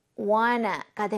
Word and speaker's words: wanna: 물론 하나씩 끊어 읽으면 그렇지만 이것을 부드럽게 발음하면 wanna가 됩니다. – I don't wanna do 라고 다시 I don't wanna do my homework.